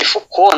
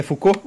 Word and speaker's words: Foucault: que foi é de Foucault, né? – É Foucault? Ah, bom.